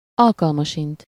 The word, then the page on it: alkalmasint: /ˈɒlkɒlmɒʃint/